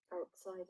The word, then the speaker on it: outsider: Outsider. So outsider. So the stress is on side. So outsider. Okay.